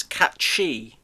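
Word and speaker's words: Cait Sith: Cat Sith, apparently the proper pronunciation of Cat Sith is Cat Chi.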